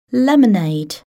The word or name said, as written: lemonade